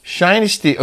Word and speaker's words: Shinesty: Shinesty. Sounds like dynasty. I didn't know how you said it. And they told me it's pronounced Shinesty.